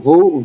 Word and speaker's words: Vu: So when my friends chant Who Dat, my last name is spelled Vu, V-U. So when they chant Who Dat, I say Vu Dat.